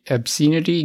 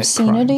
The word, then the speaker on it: obscenity: Is that really how you say it? – Yes. – But it's obscene. Obscene. – Yeah, that word has an E on the end. Obscenity does not.